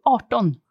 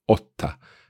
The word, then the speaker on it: Arton: So it's they have it's changed to Arton. – Arton. So how do you spell that? – A-R-T. – Ah, right. Okay. So it's still quite a long vowel, but it's not the O, Otta.